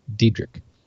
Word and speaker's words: Dietrich: He also used the example of Einstein and Diedrich, where again, D-I-E-T-R-I-C-H, Diedrich.